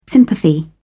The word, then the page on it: sympathy: British English